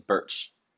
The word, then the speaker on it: Bertsch: My last name is Birch, and it's not spelled like the tree. It's pronounced like the tree, but it's spelled B-E-R-T-S-C-H.